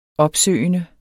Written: opsøgende

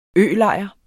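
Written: ØLEJREN

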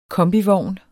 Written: kombivogn

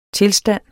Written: tilstand

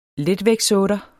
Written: letvægtsotter

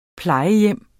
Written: plejehjem

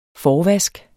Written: forvask

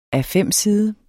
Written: A5-side

